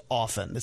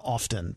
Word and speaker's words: often: I know that it used to be back in the day, if you pronounce the T in often, you were a weirdo. Nobody said the T in that word. Now it has switched. So the vast majority of people now pronounce the T. – Okay. – I do not. I'm old school. It's just often. It's not often to me. But I spell it the way it should be. With the T, I pronounce it without the T.